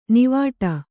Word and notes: ನಾವಾಟ: Kannada

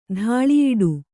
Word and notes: ತಲೆಯುಡೆ: taleyuḍe; kannada

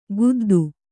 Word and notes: ಖುದ್ದು: kannada

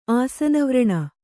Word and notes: ಆಸನವ್ರಣ: Kannada

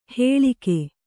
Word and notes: ಹೇಳಿಗೆ: hēḷige

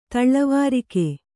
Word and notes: ತಲೆವರಿಗೆ: kannada

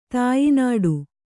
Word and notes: ತಾಯ್ನಾಡು: tāynāḍu

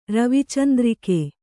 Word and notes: ರವಿಚಂದ್ರಿಕೆ: Kannada

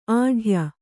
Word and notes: ಆಢ್ಯೆ: āḍhye

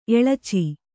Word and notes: ಎಳಚಿ: eḷaci

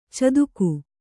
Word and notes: ಚಾತಕಿ: cātaki

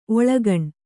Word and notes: ಒಳಗಾಣ್: Kannada

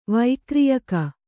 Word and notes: ವೈಕ್ರೀಯಕ: vaikrīyaka

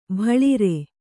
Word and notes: ಬಾಳರ: bāḷara

